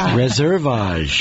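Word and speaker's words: Reserveage: And our special guests for today are who are here on behalf of Resveraj. Am I saying it right? – Reservage. – There you go.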